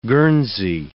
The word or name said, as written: Guernsey